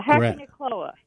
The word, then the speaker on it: Hakonechloa: It's spelled H-A-K-O-N-E-C-H-L-O-A. – Yeah, Hackonichloa.